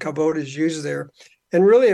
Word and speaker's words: Kavod: For the first thing, you pronounced it correctly. Most people pronounce it Kavod, but it should have an E at the end in the English transliteration.